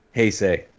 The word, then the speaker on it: Heisei: Heisei.